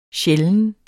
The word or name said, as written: sjælden